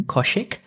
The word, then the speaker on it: Kaushik: So my first name is Kaushik. Mm-hmm. Kaushik. And if people can't pronounce my name, I always refer to Koshi from Sunrise with a K at the end.